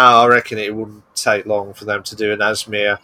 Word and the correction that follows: ASMR: It's ASMR. It's just the letters. It's ASMR.